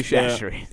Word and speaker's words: Chachere's: Tony Chachere's. – So Tony Chachere's.